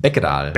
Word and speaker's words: Beckedahl: Beckedahl. – Beckedahl. – Ich das spricht man immer so aus, als hätte mit Doppel-K geschrieben.